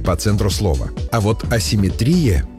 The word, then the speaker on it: асимметрия: Асимметрия. Слово, в котором ударение часто ставят по аналогии. Есть симметрия и получается асимметрия, но литературная норма иная. Асимметрия с ударением на «и».